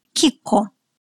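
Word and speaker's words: Chicco: Коме, комодо, амика, амике, кико. Известная марка товаров для детей, каких только версий я не слышала. Теперь вы знаете, как это слово читается и почему.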